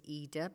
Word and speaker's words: Ida: And by the way, I pronounce it Ida because Ida Rubenstein, because her name came, she chose Ida, her real name was Lydia. So Lydida, you know, Lydida. So Ida.